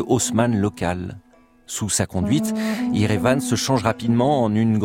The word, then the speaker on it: Yerevan: L'Arménie et ses 800 000 habitants, abandonnés par les Alliés, se replient alors sur ses montagnes et sur sa nouvelle capitale, Erevan, ou plutôt Yerevan, comme on la prononce là-bas.